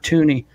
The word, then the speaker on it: Thuney: Him and Tooney, however you pronounce it. It's T-H-U-N-E-Y. I've heard Thuney. I've heard Tooney.